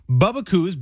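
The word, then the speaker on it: Bubbakoo's: Bubba Coo's, B-U-B-B-A-K-O-O-S. – Oh, just the way it sounds.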